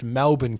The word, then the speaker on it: Melbourne: Melbourne correctly, because Americans tend to say Melbourne. – Melbourne, yeah. – And he was specifically like, I know it's not Melbourne, it's Melbourne.